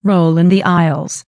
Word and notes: roll in the aisles: American English

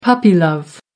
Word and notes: puppy-love: American English

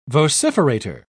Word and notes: vociferator: British English

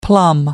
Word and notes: plum: American English

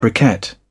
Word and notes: briquette: British English

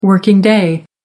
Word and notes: working day: American English

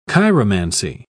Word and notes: chiromancy: British English

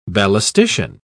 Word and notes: ballistician: British English

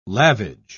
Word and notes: lavage: British English

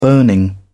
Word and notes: burning: British English

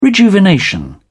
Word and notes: rejuvenation: British English

